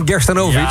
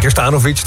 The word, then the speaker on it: Gerstanovic: We hebben het over Winston Gerstanovic. – Ja. – Of Gerstanovic, dat is maar net hoe je de klemtoon legt.